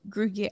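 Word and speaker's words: Gruyère: Gruyere.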